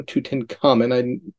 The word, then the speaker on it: Tutankhamun: Tutankhamun. That's a correct pronunciation according to Google.